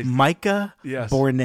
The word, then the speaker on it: Micah Bournes: It's Michael Bournes. – You just said Michael Bournes. – Dang it. Golly. – Micah Bournes. – Yeah, spell it. – M-I-C-A-H. – Yeah. – B-O-U-R-N-E-S.